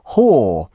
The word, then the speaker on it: HOA: The South Korean group spelt H-O-A, pronounced whore.